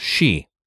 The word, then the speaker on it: sidhe: Well, just the old Celtic for she, the type of fairy, which I'm going to mention in a minute, is S-I-D-H-E. So you would think city, but everything you look up, it's pronounced she.